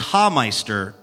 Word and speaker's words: Hameister: It's Hameister, like you're laughing,